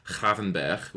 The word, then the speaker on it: Gravenberch: Like, the great contradiction here is, you know, people are complaining, not necessarily complaining, but a little bit mystified about why we've brought Gravenberch, which is apparently how you pronounce his name.